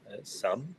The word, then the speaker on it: some: Es bueno que aquí el some suena como una A, some, y el any, okay, para que lo tengamos claro.